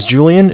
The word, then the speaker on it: Julian: All right, my name is Julian. It's J-U-L-I-A-N. And I'm calling from Bank of Cardiff. It's spelled C-A-R, D as in David, I-F-F as in Frank.